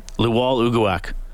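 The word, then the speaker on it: Lwal Uguak: What's the pronunciation on the name? – Luol Ugoak. – Oh. – Luwaluguak.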